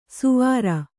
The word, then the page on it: ಸುವಾರ: Kannada